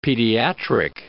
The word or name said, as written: pediatric